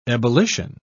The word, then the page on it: ebullition: British English